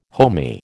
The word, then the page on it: homey: American English